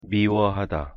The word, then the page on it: 미워하다: Korean